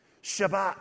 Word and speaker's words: Shabbat: Shabbat, that's how you say it in Hebrew.